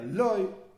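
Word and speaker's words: Loy (לא): Y en la práctica se pronuncia exactamente de la misma manera, Loy,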